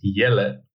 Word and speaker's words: Jelle: The Dutch way is Jelle. It's like yellow, but a little bit different.